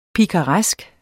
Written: pikaresk

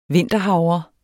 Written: vinterhavre

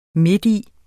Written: midti

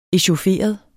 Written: echaufferet

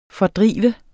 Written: fordrive

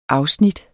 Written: afsnit

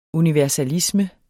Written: universalisme

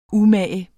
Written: umage

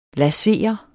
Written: laser

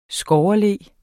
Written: SKOGGERLO

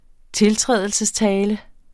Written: tiltrædelsestale

